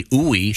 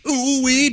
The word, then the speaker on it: Ooi: It's spelled O-O-I, and it says, according to the pronouncer here, it's long U and then double E. So it's Dr. Uwe. – Uwe. Ooh-wee, doctor.